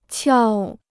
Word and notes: 撬: qiào

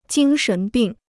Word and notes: 精神病: jīng shén bìng

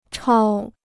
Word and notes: 超: chāo

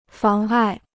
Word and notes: 妨碍: fáng ài

